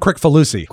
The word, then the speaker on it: Kricfalusi: John Kricfalusi. – Kricfalusi.